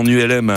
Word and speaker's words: Ulm: Donc, votre baptême en ULM a gagné cette semaine. ULM, donc les lettres, forment également le nom d'une ville européenne dont la prononciation est Ulm.